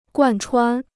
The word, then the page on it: 贯穿: guàn chuān